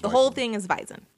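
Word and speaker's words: Wiesn: It's pronounced Weizen.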